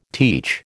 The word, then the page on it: teach: American English